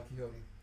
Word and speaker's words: Quijote: Is it spelled like Don Quixote? – No. – Oh. – It's Quijote. – Yeah, Don Quixote.